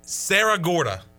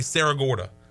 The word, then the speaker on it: Cerro Gordo: Sarah Gorda. But it's spelled C-E-R-R-O-G-O-R-D-O. But I've heard people say Sarah Gorda.